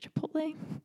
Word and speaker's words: Chipotle: I can never say this right, Chipotle. – Yeah, you said it right.